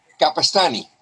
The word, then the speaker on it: Capestany: Hey, Keo, how do you say your last name? Capestany. Capestany. Okay. Capestany. It's great.